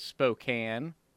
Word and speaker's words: Spokane: So Spokane is what Spokane.